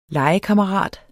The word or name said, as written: LEGEKAMMERAT